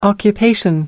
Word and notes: occupation: American English

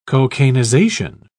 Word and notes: cocainization: British English

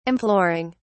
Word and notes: imploring: British English

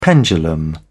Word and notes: pendulum: British English

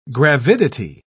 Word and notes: gravidity: British English

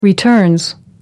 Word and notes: returns: British English

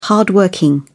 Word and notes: hard working: British English

